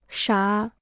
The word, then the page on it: 傻: shǎ